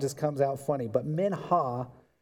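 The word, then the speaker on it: mincha (מנחה): It is the word mincha. And you really should be able to hawk a loogie somehow in there to pronounce it correctly.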